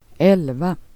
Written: el

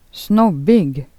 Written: snobbig

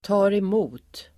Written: tar emot

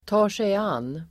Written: tar sig an

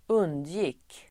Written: undgick

